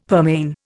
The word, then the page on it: pearmain: Indian